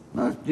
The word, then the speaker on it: Merck: Merck diye orada hep ya, öyle telaffuz edildi de evet.